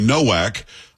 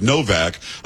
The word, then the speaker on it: Nowak: But it's the case of, I've been saying, Henry Nowak. Kay, of course, is from the UK and says that it's really not. It's pronounced Novak. A W is pronounced V.